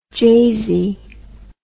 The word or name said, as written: jazey